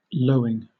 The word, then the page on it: lowing: British English